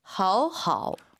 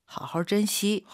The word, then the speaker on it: 好好: 그러니까 이게 이제 지금 그대로 글자 그대로는 好好 맞는 발음인데 회화에서는 사실은 好好도 쓸 수 있지만 많은 사람들이 또 好好 이렇게 이제 일성으로 올리면서 얼화가 나오죠.